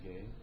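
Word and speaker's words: gay (γῆ): There's another word in the Greek which is pronounced gay. Actually, it's G-E, but it's a long E, so it's pronounced gay.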